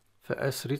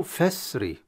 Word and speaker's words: فأسر: كلمة فأسر بأهلك، فأسر طبعا رواية حفص يقرأ فأسر بأهلك، أسر بهمزة سين وراء، همزة قطعية، لأن فأسر الهمزة وقعت في الوسط ونطقت وحققت. هذه الكلمة في قراءتين، فأسر أو فسري، فسري بهمزة وصل. – بهمزة وصل، يعني حذفت عندما نطقت الهمزة في الوسط، فسري.